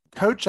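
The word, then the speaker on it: Kocs: Coach actually comes from a little town in Hungary that is named Coach. It's spelled like K O C S I or something crazy that's pronounced Coach.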